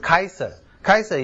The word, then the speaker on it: Caesar: 该撒利亚其实不是一个简单的字眼，该撒利亚是遵从 Caesar 的，就是凡是跟该撒两个字有关的，Caesar 是我们英文的发音哈。如果是照拉丁文的，就是 Kaiser 也是德文里面的 Kaiser，就是皇国王的意思哈。